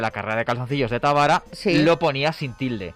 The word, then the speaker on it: Tábara: Pronuncia Tábara en esdrújula.